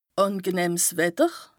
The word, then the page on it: àngenëhms Wetter: Bas-Rhin; Strasbourg